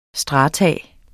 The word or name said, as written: STRATUMS